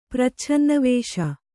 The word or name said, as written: ಪ್ರಚ್ಛನ್ನವೇಷ